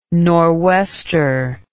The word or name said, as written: norwester